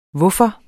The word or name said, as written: hvorfor